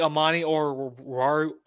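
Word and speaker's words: Amani Oruwariye: Like Amani or Warrior? – Yeah, I think that's how you say it.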